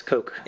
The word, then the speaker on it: Koch: Yeah, not Coca-Cola, but Koch, K-O-C-H. They kept saying Coke. I thought it was Koch, but... – No, it's Coke.